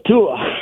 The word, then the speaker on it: Tua: Tua.